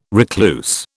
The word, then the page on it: recluse: British English